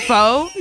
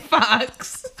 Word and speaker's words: faux: I learned that faux is pronounced faux, not fox.